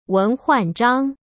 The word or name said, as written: Wen Huanzhang